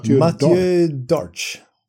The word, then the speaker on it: Mathieu Darche: Mathieu Darche.